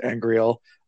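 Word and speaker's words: angreal: And then she brings up also an Angriel, and that's pronounced Angriel.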